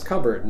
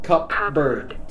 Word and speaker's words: cupboard: When you say cupboard, the P should be silent. – Yes. – It's cupboard, not cupbird.